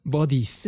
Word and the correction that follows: buddies: Disculpa mi pronunciación. – Somos buddies.